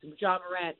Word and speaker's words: Ja Morant: I mean, I know that Murray stayed in Ja Morant. I don't know if I'm pronouncing his name wrong. But Ja Morant.